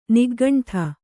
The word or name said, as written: ನಿಗ್ಗಂಠ